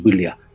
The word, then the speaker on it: Balea: Encontré que había un lago que tenía todas las posibilidades de ser, y es el lago Bilia, escrito Balea, con B, pero por la pronunciación es Bilia.